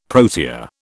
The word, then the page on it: protea: British English